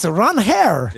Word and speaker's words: Ranger: So for those who didn't see it, at his introductory press conference, a Boston reporter asked Ranger how he pronounces his name. And Ranger said, it's Ron Hare. – Yeah. – And then you can call him Ranger if you like, but his name is Ron Hare.